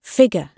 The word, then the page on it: figure: British English